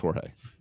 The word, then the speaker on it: Jorge: He cannot translate why it's pronounced Jorge when it's spelled George.